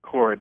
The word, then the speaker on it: Cord: It's cord, like an extension cord.